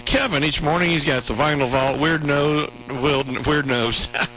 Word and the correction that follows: Weird News: Said that wrong. That could be a weird nose as well. Weird News and Hold My Beer.